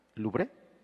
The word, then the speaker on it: Louvre: Allá en Francia, Josué, hay un museo muy famoso que se llama Louvre, ¿así se pronuncia? Eh, así.